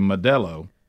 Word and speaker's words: Modelo: beer to Modelo. Am I saying that right? – Yes.